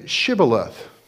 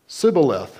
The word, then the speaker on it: shibboleth: They said to him, "Then say Shibboleth." And he said, "Sibboleth." So they said, "Say Shibboleth." And they would say, "Sibboleth." For he could not pronounce it right.